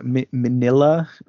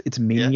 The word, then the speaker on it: Minya: Like Minya or Manila, right? But it's Minya, yeah, is how it's pronounced.